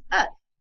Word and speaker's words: œuf: And so without the L, it's pronounced œuf, which means that it has also same derivative of the word ovary.